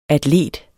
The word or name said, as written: atlet